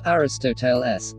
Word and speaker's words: Aristoteles: Aristotle, Aristotle, 3 Greek, rho iota sigma tau omicron tau lambda eta sigma Aristoteles, pronounced Aristoteles,